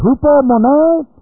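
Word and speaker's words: Hupomone (ὑπομονή): The word endurance is the word hupomone. Hupomone. H-U-P-O-M-O-N, long E. Hupomone.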